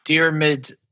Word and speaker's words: Diarmuid: My real name is Diarmuid Considine, D-I-A-R-M-U-I-D, Considine.